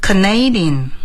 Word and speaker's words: Canadian: 好，下面一个加拿大的 Canadian。 – Canadian，我们一说加拿大 Canada， Canada 这是加拿大的国家的名称 Canada。 Where are you from? I'm from Canada。但是一变成形容词就变成 Canadian， Canadian 加拿大的，发音就有很大的变化，大家一定要记住 Canadian。